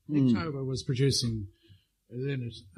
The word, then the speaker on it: Lynott: Or he's meant to be called Lynott, actually. That's his the correct pronunciation, but I have no recall of how it came about.